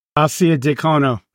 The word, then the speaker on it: Arcidiacono: R- Arcidiacono, RC, RC, that's Italian, pro. You should fucking know that. How do we say it? Arcidiacono.